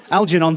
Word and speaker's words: Algernon: I don't know if that's how... Algernon, thank you. See, I don't even know how to pronounce his name. There we go. Algernon.